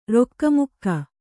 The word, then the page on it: ರುಕ್ಮಕ: Kannada